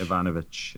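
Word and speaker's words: Evanovich: Ivanovich.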